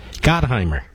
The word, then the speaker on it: Gottheimer: Gottheimer.